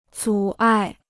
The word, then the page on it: 阻碍: zǔ ài